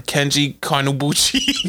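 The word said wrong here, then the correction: Keiji Kinebuchi: And Keiji Kinabuchi.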